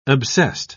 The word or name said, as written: obsessed